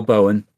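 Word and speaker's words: Boen: Am I saying it right? Boone? – Earl Bowen. Earl Bowen, specifically, yeah.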